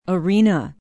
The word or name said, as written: arena